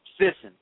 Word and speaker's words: Thyssen: And now his second name is Thyssen, but it's spelled T-H-Y-S-S-E-N. Thyssen. T-H-Y-S-S-E-N.